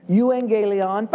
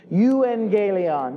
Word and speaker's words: euangelion: euangelion. By the way, everybody say that with me tonight. Pronounce that with me. Euangelion. One more time.